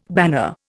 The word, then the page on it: banner: Indian